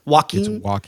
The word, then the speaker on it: Joaquin: Joaquin? How do you say it? – Joaquin.